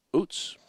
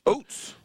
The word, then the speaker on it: Ouzts: He has a great Scrabble last name, Coach. O-U-Z-T-S. Utz, pronounced. – Utz.